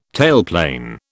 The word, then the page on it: tailplane: British English